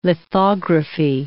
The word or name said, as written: lithography